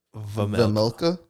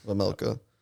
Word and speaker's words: Vejmelka: I don't know how to say his name. – Don't say the J. – Vejmelka. – There you go. – Vejmelka.